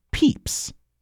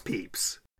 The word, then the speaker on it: Pepys: His last name is spelled P-E-P-Y-S, and I'm pretty sure that is pronounced Peeps, like the little marshmallow things. – You got it. It is Peeps.